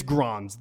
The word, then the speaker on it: Grans: Helge Granz, is that the pronunciation here? I've heard it a couple times, but I've already forgotten. – I've heard Helge. I've heard Helga. – Helga. – Like, hey, Arnold. And then I've also heard Helge. – Hey, Arnold. – I know that it's Grans, though.